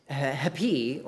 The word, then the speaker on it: epi: And the word for upon is hepi, or E-P-I, with an H sound at the beginning.